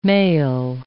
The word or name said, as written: male